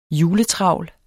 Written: juletravl

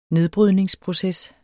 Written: nedbrydningsproces

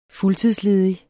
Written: fuldtidsledig

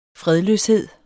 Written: fredløshed

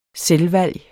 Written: selvvalg

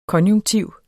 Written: konjunktiv

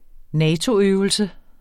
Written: NATO-øvelse

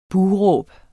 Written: buh

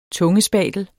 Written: tungespatel

